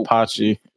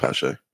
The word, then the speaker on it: Pache: Apache, or however you say his name, is in left. Pache? – I think it's Pache. Okay. But don't quote me on that.